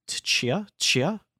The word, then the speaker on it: Tchia: But uh, I'm probably gonna pronounce this wrong, but I think it's pronounced Tchia Chia, T C H I A.